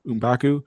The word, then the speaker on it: M'Baku: So they go back to, M'Baku? Is that how you pronounce it? – M'Baku.